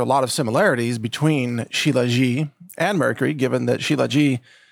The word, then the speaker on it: Shilajit: We have both Zero Hedge and the Epoch Times now promoting a substance called Shilajit, which it looks like it might be pronounced Shilajit, but I think it's pronounced Shilajit, S-H-I-L-A-J-I-T. And the headline from the Epoch Times,